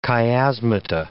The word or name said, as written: chiasmata